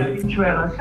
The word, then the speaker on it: Habichuela: Habichuela, c'est bien.